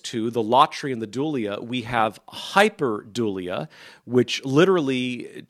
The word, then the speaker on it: dulia: Secondly, would be dulia, just how it sounds. It is spelled D U L I A, like like the feminine name Julia, but with a D.